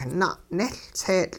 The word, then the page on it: na'nehLtseetL': hupa